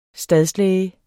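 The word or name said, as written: stadslæge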